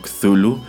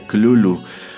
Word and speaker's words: Cthulhu: Ahora, en lo que respecta a la pronunciación, bueno, yo llevo todo lo que va del programa pronunciándolo Cthulhu, que, bueno, es una pronunciación muy común tratando de, pues, seguir la manera en que se deletrea al interior de estos cuentos. Sin embargo, a decir de Lovecraft, por la manera en que él lo explicó, en realidad una pronunciación más acertada sería Clulu, aunque pues de manera más eh, gutural, porque a fin de cuentas se supone que es una palabra eh, que originalmente pronunciaban criaturas que no son de este mundo.